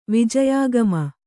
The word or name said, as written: ವಿಜಯಾಗಮ